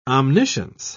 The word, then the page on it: omniscience: British English